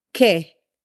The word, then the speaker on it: que: So what in Spanish is said que, and it is spelled Q-U-E.